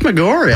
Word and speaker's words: Maggiore: And then at its southern end is Lake Maggiore. Did I pronounce that right? – I think it's Maggiore. Maggiore. I think it's mispronounced, okay.